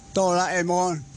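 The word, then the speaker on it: 哆啦A梦: 佢就是小朋友口中嘅哆啦 A 梦，其实正確发音是哆啦 A 梦，亦是我哋大朋友心目中嘅老友记啊！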